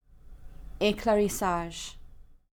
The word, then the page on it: Éclaircissage: French